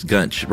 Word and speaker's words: Gunch: Yes, Gunch. Rhymes with lunch, she says.